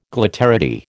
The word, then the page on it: glitterati: American English